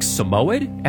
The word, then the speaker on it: Samoyed: It's like Samoyed? S-A-M-O-Y-E-D is the dog breed's name.